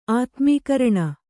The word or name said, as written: ಆತ್ಮೀಕರಣ